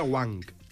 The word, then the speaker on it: Wang: I el que m'ha fet gràcia és que el mateix Juan, al vídeo de... – Jo diria que es pronuncia Wang.